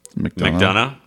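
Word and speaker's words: McDonagh: How do you say the director's name? – McDonough. – McDonough, okay. – Like McDonough, Georgia, where they make purple mattresses. – Exactly like that.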